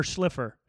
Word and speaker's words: Shlifer: So Dr. Gary Shiffler, or Shlifer, Shiffler? Oh, shit. I'm sorry, Gary. I might have spelled your fucking name wrong, dude. Let me see it right now. Dr. Gary Shlifer, S-H-L-I-F-E-R, at Dr. Gary Evolve on Instagram.